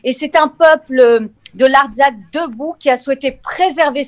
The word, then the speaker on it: Artsakh: Donc, en effet, qui concerne l'appel des artistes français en faveur de l'Arménie et de l'Artsakh, je ne sais pas si je prononce bien, publié dans le Figaro de... Pardon ? – L'Artsakh. – Oui, l'Artsakh. Donc, voilà.